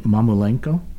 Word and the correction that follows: Mamulengo: Yeah, Mamulengo.